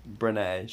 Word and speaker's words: Brené: Is it Bren? Bren Brown? Whatever. – Brené. – She has an accent on the second E.